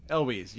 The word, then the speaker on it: Elwes: Elwes, yeah.